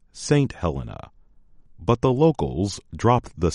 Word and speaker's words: Helena: Helena, Montana. The capital city of Montana is pronounced Helena, not Helena. That is how most women named Helena say their name.